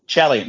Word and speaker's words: Chalian: Is Chalian? – Chalian, yeah, David Chalian, our political director, yeah.